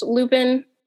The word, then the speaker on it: Luypen: I think his name is pronounced Lupin, but I'm not 100% on that. L U Y P E N.